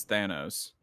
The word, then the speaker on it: Thanos: I'm pretty sure it's Thanos.